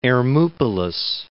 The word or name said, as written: Hermoupolis